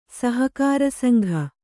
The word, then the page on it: ಸಹಕಾರಿಸಂಘ: sahakāri sangha